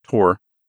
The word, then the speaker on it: Thor: It's spelled T-H-O-R, like Thor, but it's pronounced Tor.